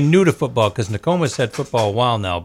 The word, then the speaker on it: Nokomis: They got a break when MCI lost, and honestly, I don't know how to pronounce the team MCI lost to. N-O-K-O-M-I-S. – Oh, Nokomis.